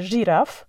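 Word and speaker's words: Zsiráf: Jest jeszcze langosz Land, jest takie bardzo sympatyczne miejsce również w Budapeszcie, myślę, że spokojnie możecie sobie je wygooglować, które nazywa się Żiraf. Pisze się z, s, i, r, a, f. Z, s się pisze ż.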